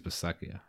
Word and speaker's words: Bisaccia: How do you say his name? Bisaccia? The Raiders intern right now. – I think it's Bisaccia, I think.